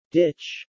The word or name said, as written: Ditch